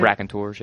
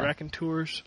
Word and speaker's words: Raconteurs: Rock Band is getting another pack featuring the Raconteurs. Is that how you say it? – Raconteurs, yeah. – Raconteurs.